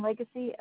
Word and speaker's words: Lagace: Shannon, last name Legacy, L-A-G-A-C-E.